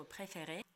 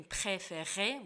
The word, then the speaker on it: préféré: Посмотрите, например, на слово «préféré», как здесь произносится мягко «р». Если мы сравним его с неправильным произношением «préféré», вот «р» не должно быть ни в коем случае, да, это, и не должно быть никакого там то же самое «préféré», вот не надо, просто «préféré», мягкий, легкий,